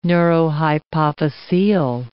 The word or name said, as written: neurohypophysial